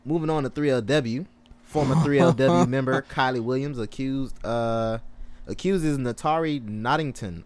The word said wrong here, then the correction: Naughton: Did I say her name right? – I thought it was Natari Naughton. – Natari Naughton. Okay, yeah.